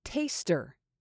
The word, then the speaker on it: taster: Last one, taster. Remember not to pronounce this word as test. It's taste, with that long A vowel sound and an R at the end.